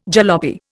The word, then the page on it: jalopy: Indian